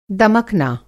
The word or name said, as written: दमकना